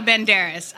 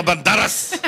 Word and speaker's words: Banderas: I'm torn as well because, I mean, Antonio Banderas. I could listen to him read the dictionary, right? – I'm sorry. You didn't say it right. It's Antonio Banderas.